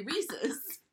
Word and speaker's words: Reese's: I know it's supposed to rhyme and it's supposed to rhyme with the word that I do know how to say. So it has to be Reese's.